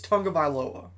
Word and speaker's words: Tagovailoa: And then look at, like, looking at Tua, and most people do not know how to pronounce his last name. It's, his last name's Tagovailoa.